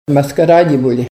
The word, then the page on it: maskaradibuli: Ninilchik Russian